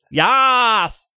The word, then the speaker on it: Yas: At Yas Bay. Did I say that right? – Yas! – Yas? Like Yas Queen? – Don't ever say those words ever again.